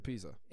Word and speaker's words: Pisa: Yeah, Torino, Pisa, or how do you say it? – Pisa. – It is just Pisa.